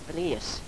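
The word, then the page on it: bres: dialetto croato di Lukezi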